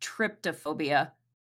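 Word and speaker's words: trypophobia: A person with autism might develop triphobia, a fear of irregular patterns, clusters, bumps, or small holes, for example. – But just to clarify, that's pronounced tryptophobia.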